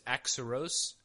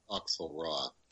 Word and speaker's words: Auxerrois: It was Axaros? – Well, I believe it's pronounced Axelrod, but Axelrod? Okay. – Spelled AUXERROIS.